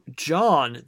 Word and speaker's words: Jean: I don't know how Although John, that his name is spelled J-E-A-N, but instead of being pronounced Gene, it's John.